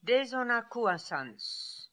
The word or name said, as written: Dezonakooasanz